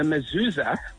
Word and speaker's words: mezuzah: So what I want to tell you is the mezuzah, you pronounce it correctly.